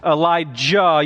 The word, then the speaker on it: Elijah: You want to make sure that when you pronounce Elijah, you pronounce the Jah, because there's also an Elisha in Scripture.